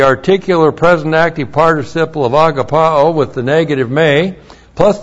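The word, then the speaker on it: agapao (ἀγαπάω): The verb is A-G-A-P-A long O, agapao. From the verb, we get the noun agape. A-G-A-P long E, and both of them are words that describe and deal with virtue love.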